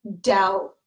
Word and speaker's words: doubt: D O U B T, and the B is silent. We do not say doubt, doubt. No, it is doubt, doubt.